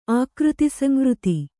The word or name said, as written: ಆಕೃತಿಸಂವೃತಿ